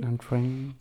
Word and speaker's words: Antrim: Antrim, Antrim no sé cómo se pronuncia exactamente, Antrim.